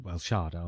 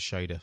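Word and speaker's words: Shada: Going back to pronunciation, I thought it was Shader for years. – Yeah. Like, and, I mean... – Because you only saw it in text.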